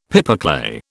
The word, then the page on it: pipeclay: British English